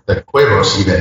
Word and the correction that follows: cuevas: Did I pronounce that right? Cuevos? – Cuevas. – Cuevas. – It's like cave. – Right, okay. – Yeah, it's cave. Like a cave because it's dark.